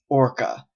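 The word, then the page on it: orca: Canadian English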